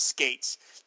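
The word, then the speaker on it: Skeates: I read an interview Steve Skates gave in either the Charlton Spotlight, Back Issue, or Alter Ego, I think, where he set the record straight on how his name is said. My memory's a bit fuzzy, but I recall him saying that it's pronounced Skates.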